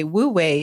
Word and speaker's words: Wu Wei: And then the way, which by the way, Wu Wei is spelled W-U-W-E-I. But it conveniently sounds like the word W-A-Y, way, in English, because it is about your way, W-A-Y.